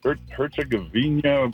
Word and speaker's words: Herzegovina: Herzegovina.